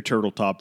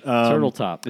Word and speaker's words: Turteltaub: Let's go through Turteltaub for a second. – Turteltaub. Is it literally spelled like? – It is J-O-N space turtle. Oh, okay. But T-U-R-T-E-L Taub. T-A-U-B.